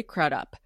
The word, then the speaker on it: Crudup: Claire Danes and Billy Crudup, pronounced Crudup, we learned.